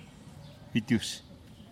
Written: bitiux

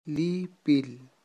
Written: lipil

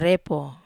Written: repo

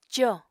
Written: còo

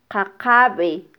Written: qaqabe

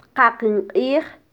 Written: tqaqin qij

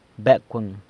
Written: Becwëng

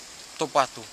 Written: topatu

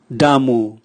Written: yam

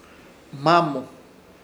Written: Hmamo

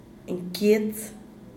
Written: nkiets

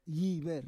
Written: yiver

